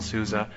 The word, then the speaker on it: SUSE: And also, I heard on a video once, it was an executive from SUSE, a German individual, an executive, from the original SUSE, and he was pronouncing it SUSE, just like Susie, but with an A, SUSE.